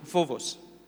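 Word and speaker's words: phobos (φόβος): phobos. It, it's spelled with a B, but it's pronounced with a V.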